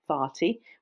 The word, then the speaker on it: Fatih: But in this part of town, which is called Fatih, which is spelled F-A-T-I-H and means the conquest and nothing worse.